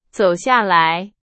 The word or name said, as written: 下来